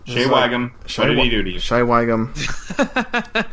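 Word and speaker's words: Shea Whigham: Shia Wiggum? Is that his name? – Shay Wiggum. – Shay Wiggum, thank you.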